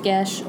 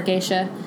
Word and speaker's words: geas: Those who are under a Gesh, and just to clarify, that is spelled G-E-A-S. It is Celtic. It's pronounced Gesh or Geisha.